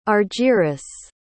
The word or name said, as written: argyris